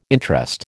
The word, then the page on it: interest: American English